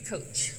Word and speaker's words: Koch: Steve, I don't know how to say this last name. K-O-C-H. – Steve Coach.